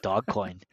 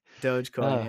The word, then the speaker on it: Dogecoin: Is that how you pronounce it? I've been calling it Dogcoin.